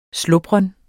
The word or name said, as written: slubren